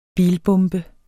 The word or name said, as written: bilbombe